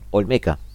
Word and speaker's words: Olmeca: el tren Maya o la refinería Onteca, perdón, Olmeca, lo pronuncié mal,